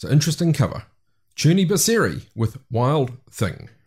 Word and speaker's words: Crni Biseri: named after a film called Crni Biseri. That's my attempt at pronouncing a word spelt C-R-N-I. And then B-I-S-E-R-I.